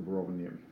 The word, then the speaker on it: Rovaniemi: We're up in Rovana. I never pronounce the name. – We're actually in Sineta, which is about 20 km north of Rovaniemi.